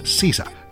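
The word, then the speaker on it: SZA: El nombre artístico de Solana y Manny Rose escribe SZ pero se pronuncia Sisa.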